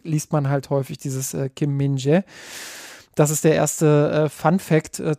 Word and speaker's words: Kim Min-jae: Der heißt Kim Min-jae. – Kim Min-jae, genau. Ja, du hast nochmal auf YouTube, nur falls jetzt jemand mit der Stirn runzelt, auf YouTube nochmal nachgeschaut, wie wird er denn ausgesprochen?